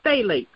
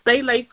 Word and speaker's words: phthalates: The next chemical we're going to talk about is called phthalates, and it's spelled really funny. It's phthalates with T-H-A, but it's spelled with a P, P-H-T-H-A-L-A-T-E-S. Phthalates.